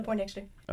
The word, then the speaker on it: Poindexter: Yeah, did I say that right? – Yeah, you said that right. Exactly. Taylor Poindexter.